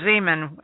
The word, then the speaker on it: Szeman: I had to get married to fix mine, so I was a Zeman, S-Z-E-M-A-N, and nobody get that right, so I got married and married a Holstrand, which is a lot easier to spell.